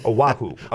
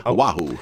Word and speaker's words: Oahu: Oahu. – Oahu.